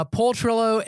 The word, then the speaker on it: Paul Trillo: Paul Trillo